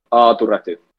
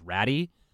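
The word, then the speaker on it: Aatu Räty: Well, this is mainly just for that texter that's repeatedly texting in that we're pronouncing it wrong, and it's ratty. – It's not. – It's not ratty. It's Toronto. – It's very melodic.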